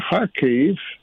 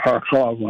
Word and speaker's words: Kharkiv: So it was a big land grab there, in the east, and even in the north around Kharkiv, or Kharkov, however you want to pronounce it,